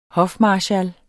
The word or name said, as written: hofmarskal